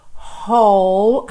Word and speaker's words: whole: Whole, atenção à pronúncia dessa palavra, tá? Porque é bem diferente do que aparenta quando a gente lê. Então, ó, W-H-O-L-E, whole, whole, whole life. Eu junto tudo aqui porque é como se eu tivesse dois L's ligados, já que o E do whole não é pronunciado, tá? E a minha próxima palavra é o through. Começa f-f-f também em linguinha lá nos dentes da frente.